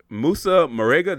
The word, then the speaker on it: Moussa Marega: Where um, I hope hope I'm saying his name right. So uh Moussa Marega. Did I get that right? – Yes. – Yes. Okay. Awesome.